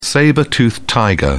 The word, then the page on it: sabre toothed tiger: British English